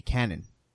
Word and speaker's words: Kanan: He said it's not Kanan, it's pronounced something else. – It's pronounced the way it would look like if it was spelled with a C, Canon.